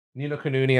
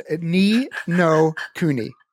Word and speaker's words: Ni no Kuni: Ni no Kuni. I'm still working my way through that. – I'm sorry. What was that? – Ni no Kuni. – A couple more syllables. Ni no Kuni.